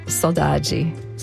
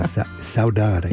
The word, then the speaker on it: saudade: And I have the Brazilian pronunciation, saudade. So my partner is Brazilian. – Oh, okay. Yeah, saudade, I guess the word is.